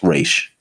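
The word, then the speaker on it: Ra's: Oh, it's Raz Agul, and like yeah. no, it's it's it's pronounced Raish.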